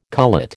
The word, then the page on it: cullet: American English